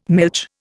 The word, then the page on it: milch: Indian